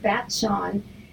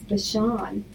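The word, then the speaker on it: Batshon: It's spelled B-A-T-S-H-O-N, I think, and I pronounce it Bat-Shawn, and it's Bashan.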